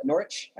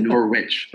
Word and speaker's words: Norwich: If you are in Norwich, did I say it right? – You said it right. Wow. – Okay, good. – Most Americans would say Norwich, but Norwich, did a good job there.